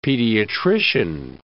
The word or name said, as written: pediatrician